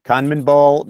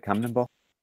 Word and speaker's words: CONMEBOL: CONMEBOL member. Did I say that right? CONMEBOL? – I thought it was CONMEBOL. – CONMEBOL. – CONMEBOL. – The CONMEBOL.